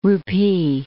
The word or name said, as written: rupee